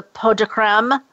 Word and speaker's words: pot de crème: the pot de crème? – Yes.